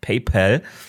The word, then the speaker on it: PayPal: Und zwar sein früherer Kollege, mit dem er PayPal, oder wie es ja eigentlich richtig ausgesprochen wird, PayPal, gegründet hat, Peter Thiel. Mhm. Elon Musk hat mit Peter Thiel zusammen PayPal gegründet.